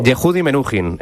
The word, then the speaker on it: Yehudi Menuhin: Pues creo que es Yehudi Menuhin, no sé si se pronuncia así, pero... – Yehudi Menuhin, efectivamente.